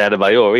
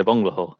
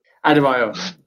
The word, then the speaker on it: Adebayor: You can't say Adebayor either, you said a bungalow. – Yeah. – He played for Aston Villa. – Adebayor.